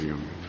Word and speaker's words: Yoma: Real, the correct pronunciation is Yoma. – Yoma. – But everybody says Yuma.